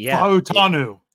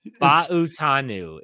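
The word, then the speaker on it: Fautanu: It's, Fa'utanu. – Fa'utanu is the pronunciation. There's four syllables. – Pacific Island, you pronounce every vowel.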